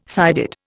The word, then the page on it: sided: Indian